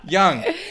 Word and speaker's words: Jung: Oh, I think it's Jung. Young? I think it's Jung. – Fuck. – You didn't say Carl Jung? – Carl Jung. Young. – You know... – Young, Jung, I can see you as like a fucking, like in a coffee shop, just fucking chilling, you know...